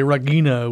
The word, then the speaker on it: Regina: psychedelic furs with house before that, we were lucky enough to hear from the Swinging Erudites and the Sugar Cubes with Regina, or as I like to say, Regina with the soft G.